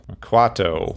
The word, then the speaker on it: Quato: Kato. Quato. – Quato. – I keep pronouncing it Kato. It's Quato.